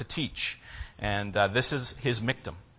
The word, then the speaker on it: miktam: That's just a transliteration of the Hebrew word into English letters. It literally is pronounced miktam.